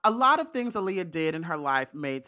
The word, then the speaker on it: Aaliyah: And I'm gonna pronounce it Aaliyah. Just to get in the habit,